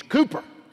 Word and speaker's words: Cowper: C-O-W-P-E-R. I would pronounce it Cowper. And I pronounced it Cowper in a seminary class one time. And I was rebuked deeply in Jesus' name. And from that day to this, I have remembered it is not Cowper, it is Cooper.